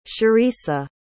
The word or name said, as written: cherisa